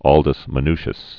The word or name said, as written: Aldus Manutius